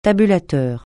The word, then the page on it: tabulateur: francais